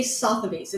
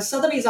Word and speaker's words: Sotheby's: I always say Sotheby's.